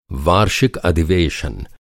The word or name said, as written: अधिवेशन